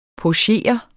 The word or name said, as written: pochere